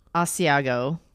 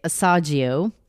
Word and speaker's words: Asiago: Okay, so we've got Asiago. – Yep. – Which we used to say Asagio.